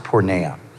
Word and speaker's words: porneia: So sexual immorality is the translation of a Greek word that is pronounced porneia.